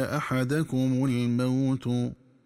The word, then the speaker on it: أحدكم الموت: ينبغي التنبه للنطق الصحيح للفظي أحدكم الموت، فتقرأ بفتح الدال في أحدكم وبضم التاء في الموت، هكذا: يأتي أحدكم الموت.